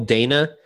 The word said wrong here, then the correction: Dano: I may be saying his name wrong. – Dano? – Is it Dino? – His Dano. Paul Dano.